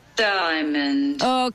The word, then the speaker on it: Diamond: Diamond.